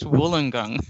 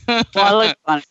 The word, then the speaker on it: Wollongong: And you did well to present and pronounce Wollongong. – Wollongong.